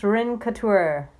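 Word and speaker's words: Rencountre: How would you pronounce that? Renkatur? – Chance Renkatur.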